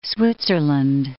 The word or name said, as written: Switzerland